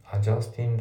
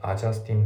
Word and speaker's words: adjusting: で、あと発音なんだけど、 adjusting の G がね、ほぼ聞こえてないんですよね。 adjusting the じゃなくて、 adjusting the ってなってます。